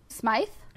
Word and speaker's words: Smythe: Smythe. – Smythe. Smythe. – Okay. Smythe. – Yes. – Okay. – Like yeah, Smith, but she with was, the, like an I in the middle.